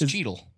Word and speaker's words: Cheetle: It's Cheetle. It's called Cheetle. – It's true. – Yeah, kind of. Right. But it's spelled C-H-E-E-T-L-E. – Mm-hmm.